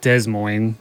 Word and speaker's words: Des Moines: Been to Knoxville, been to Boone, been to Des Moines. I used to call it Des Moines. I didn't know the S was silent for the longest time.